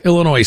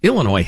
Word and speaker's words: Illinois: Illinois has terrible problems politically, economically, governmentally. It's just a train wreck. But anyway, the country pronounces the S when they say it.